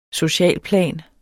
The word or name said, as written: socialplan